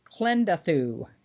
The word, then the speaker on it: Klendathu: And the drop to the bug home planet, Klendathu. I saying that right? Klendathu? – Yeah, close enough.